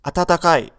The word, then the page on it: atatakai: Japanese